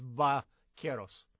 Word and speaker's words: Vaqueros: When there's a V in Spanish, it's pronounced with a B, not a V. There's no V. It's Vaqueros.